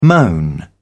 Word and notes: moan: British English

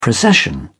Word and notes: procession: British English

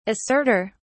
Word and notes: asserter: British English

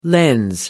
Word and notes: lens: American English

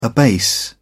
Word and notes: abase: British English

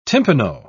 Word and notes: timpano: British English

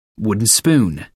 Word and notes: wooden spoon: American English

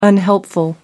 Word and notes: unhelpful: American English

